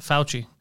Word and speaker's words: Fauci: How did that go with Johnny Fiask? – It's pronounced Fauci. And it went well.